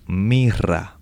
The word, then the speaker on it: myrrh: Se llama mirra, mirra. En inglés se escribe M-Y-R-R-H, mir.